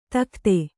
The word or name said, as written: ದಗದ